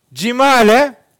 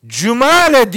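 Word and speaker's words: cimale (جمالة): Bu defa cimale halat manası veriyor. Cumale diye de okunuyor bu.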